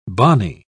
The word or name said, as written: bonny